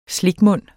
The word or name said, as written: slikmund